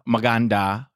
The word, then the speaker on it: Maganda: So Meganda, Maganda, uh-huh.